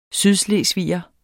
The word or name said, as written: sydslesviger